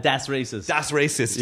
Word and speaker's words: Das Racist: Am I saying it right? Das Racist. – Das Racist. – Yeah.